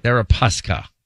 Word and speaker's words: Deripaska: How do you say his name? Deripaska. – Deripaska.